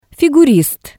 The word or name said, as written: figurist